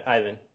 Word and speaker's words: Ivan: Ivan.